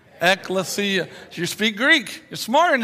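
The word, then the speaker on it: ekklesia (ἐκκλησία): And whenever you run across the word church in your New Testament, it's a translation of a Greek word that reads like this, ekklesia. Ekklesia, four syllables, okay?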